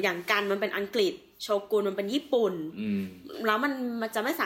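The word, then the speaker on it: shogun: แต่พอเป็น h s h o g u n ออกเสียงมันสระอุแทนคือเป็นโชกุนไม่เป็นโชกัน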